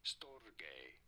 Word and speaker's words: storge: In Greek, it is normally pronounced something along the lines of Storge. – Oh. – Storge. – Storge. – Therefore, it is. – In English, it is usually said as storge.